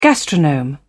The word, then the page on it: gastronome: British English